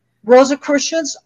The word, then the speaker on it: Rosicrucians: Rosicrucians, Rosicrucian, like I'm in a spelling test here.